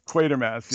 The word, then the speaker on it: Quatermass: I'm so glad you brought up, if it's pronounced Quatermass. – Quatermass, yeah.